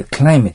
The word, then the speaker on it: climate: クリマテと書いて、 climate と言います。